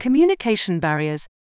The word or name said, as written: communication barriers